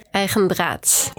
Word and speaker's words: EigenDraads: So I'm really happy to have Hilde here from, I'm going to try and pronounce it, Eigendraads? – Eigendraads. – Almost.